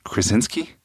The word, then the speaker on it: Krasinski: I'm not sure I have his name right, Dallin. John Krasinski? – That is accurate how you say it.